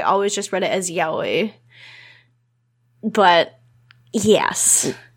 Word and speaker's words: yaoi: how likely would Carrie get into yaoi? – I hate that it's pronounced yaoi because I always say yaoi in my head.